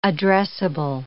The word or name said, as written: addressable